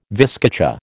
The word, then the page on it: viscacha: American English